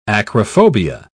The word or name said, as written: acrophobia